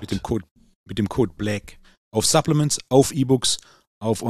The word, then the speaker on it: Black: Aber es war gut, dass du es nochmal buchstabiert hast, weil du hast es mit Ä ausgesprochen. Black. Mit dem Code Black.